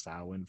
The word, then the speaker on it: Samhain: It's spelled like Samhein, but it's pronounced Samhain for some reason.